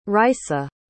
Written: raissa

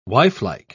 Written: wifelike

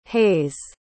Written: hayes

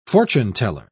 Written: fortune-teller

